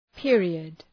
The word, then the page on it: period: ‘pıərıəd